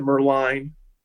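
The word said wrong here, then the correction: Moerlein: I don't think I'm saying it right. But Moreline. – Moreline. Yeah. Thank you. Moreline.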